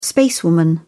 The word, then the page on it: spacewoman: British English